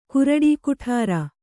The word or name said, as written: ಕುರುಡಿಕುಠಾರ